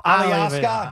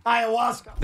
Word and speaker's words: ayahuasca: Ayahuasca. Ayahuasca.